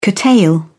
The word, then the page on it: curtail: British English